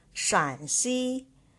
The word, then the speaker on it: Shaanxi: Remember, it is pronounced as Shaanxi, not Shanxi. There is another province called Shanxi, and, and this province is called Shaanxi, different provinces.